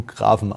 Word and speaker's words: Graphen: Nee, aber ich möchte damit sagen, also den Fehler, ganz ehrlich, also deutsche Sprache würdest du Grafen aussprechen.